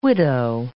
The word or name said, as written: widow